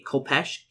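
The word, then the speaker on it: khopesh: So it is pronounced a Kopesh, Kopes.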